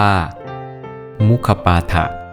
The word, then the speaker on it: มุขปาฐะ: สวัสดีครับพบกับรายการคำวัดวันนี้ขอเสนอคำว่ามุขปาฐะมุขปาฐะสะกดด้วยมอม้าสระอุขอไข่ปอปลาสระอาทอฐานสระอะอ่านว่ามุขปาฐะ